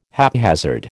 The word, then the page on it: haphazard: American English